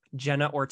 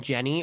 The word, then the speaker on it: Jenna: I hear people saying Jenny Ortega, and that drives me crazy because like I was questioning myself for a long time. I was like, am I saying it wrong? I'm like, is it Jenny? So I went and checked and I was like, no, it's Jenna. So no, it's Jenna, our budding scream queen too.